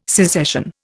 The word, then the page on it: secession: Indian